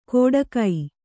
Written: ಕೊಡಗೈ

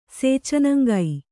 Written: ಸೇಚನಂಗೈ

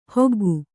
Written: ಹೊಗ್ಗು

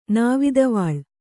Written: ನಾವಿದವಾಳ್

